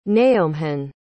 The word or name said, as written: naomhan